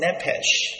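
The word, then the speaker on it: nephesh (נֶפֶשׁ): The Hebrew word for lust in this particular verse is pronounced as nephesh. It's not a bad thing for you to know the particular word, nephesh, which is translated in the scriptures to refer to a person most of the times.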